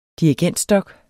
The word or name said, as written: dirigentstok